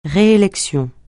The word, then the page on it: réélection: francais